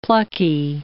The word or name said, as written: plucky